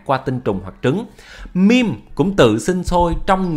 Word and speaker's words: meme: Trong tiếng Anh ta nên phát âm meme cho vần với cream. Meme là meme các bạn.